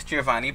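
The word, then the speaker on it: Geovanni: Again, my name is Giovanni Baena, and I'll spell that for you guys. It's G-E-O-V-A-N-N-I, G-E-O-V-A-N-N-I, and my last name is Baena, and that's B as in boy, A-H-E-N-A, just for anyone that's interested.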